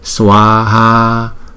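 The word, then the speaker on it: svāhā: Chữ xóa ha cũng vậy, chúng ta đọc là swaha, swaha. Bởi vì hai chữ a này là hai nhịp hết, cho nên chúng ta đọc là swaha. Cái swaha này chỉ có hai âm thôi, chứ không phải là sờ wa ha giống như trong phiên âm nhé.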